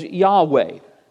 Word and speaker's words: Yahweh: And since there's no vowels, it's not certain how it's pronounced, but the tetragrammaton is generally believed to be pronounced as Yahweh.